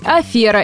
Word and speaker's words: афера: И правильно говорить «афера» и никак иначе.